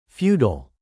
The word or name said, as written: feudal